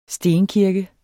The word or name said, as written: stenkirke